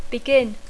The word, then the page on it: begin: English